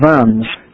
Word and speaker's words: Worms: So the next thing on your list is I guess you have to pronounce it correctly. It's the Diet of Worms. If you do it wrongly, it'll come out something very appetizing. It's the Diet of Worms, the city of Worms in Germany.